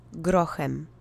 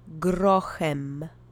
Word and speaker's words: grochem: Try to roll the R if you can. The accent is on the first syllable. Repeat after me three times. Grochem. Grochem. Grochem. And as a reminder, the CH letter combination actually produces the H sound, not chem or tchem.